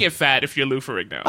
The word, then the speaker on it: Ferrigno: Lou Ferrigno.